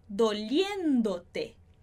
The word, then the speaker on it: doliéndote: Lembrando de alterar colocar o acento aqui para manter a sílaba forte no mesmo lugar. Que está doliéndote.